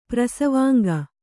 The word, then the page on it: ಪ್ರಸವಾಂಗ: prasavāŋga